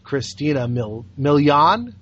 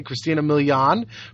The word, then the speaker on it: Christina Milian: Christina Milian. – Mm-hmm. – Is that how it's pronounced? – Yeah. – Okay, Christina Milian, from a rival label, and discovers that the record industry is packing a whole lot more than a tune.